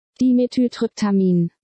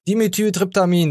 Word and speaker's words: Dimethyltryptamin: Dimethyltryptamin. – Dimethyltryptamin, da habt ihr es gehört.